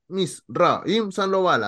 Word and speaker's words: Misraim Sandoval: Mi nombre es Misraim Sandoval, así por sílabas, como dijo Silvana.